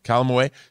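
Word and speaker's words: Calamawy: I know everyone is talking about Oscar Isaac, but can we talk about May Calame? Is that how you pronounce it? – Calamaway.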